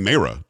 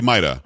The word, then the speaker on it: Mayra: I was saying Maida, but I think she's saying Mayra. To be honest with you, have you heard her say her name? Because I know in, uh, in Spanish know it would be Maida. I haven't. I haven't heard her say it. – Pretty sure it's she said Mayra. I think she's pronouncing it the English way, which is fine.